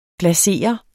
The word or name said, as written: glasere